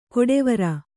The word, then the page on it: ಕೊಡೆವರ: koḍevara; kannada